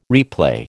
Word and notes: replay: American English

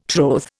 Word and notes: troth: Indian

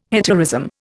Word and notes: hetaerism: Indian

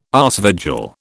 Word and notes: aasvogel: British English

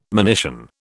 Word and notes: monition: British English

